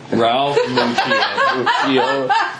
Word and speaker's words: Ralph Macchio: My conversation with Ralph Macchio. – Ralph Macchio. – That's exactly how it's pronounced.